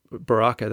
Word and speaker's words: Baraka: I found that Baraka, that's how it's pronounced, isn't it? – Yeah, yeah.